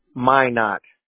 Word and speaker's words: Minot: It's Minot. – Minot. How do you spell that? – M-I-N-O-T, Minot, North Dakota. – Yeah, got it. That was super. I made that so hard. That's exactly how it sounded.